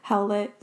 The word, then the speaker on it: Howlett: Howlett.